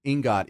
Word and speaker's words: ingot: Am I saying it correctly, please? – Ingot. – Thank you. Yep, there's the pronunciation. Ingot. You got it right.